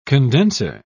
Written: condenser

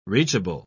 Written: reachable

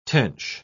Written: tench

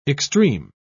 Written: extreme

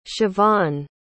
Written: chevonne

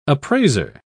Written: appraiser

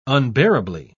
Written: unbearably

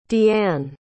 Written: deeann

